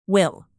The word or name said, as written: will